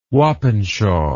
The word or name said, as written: wappenshaw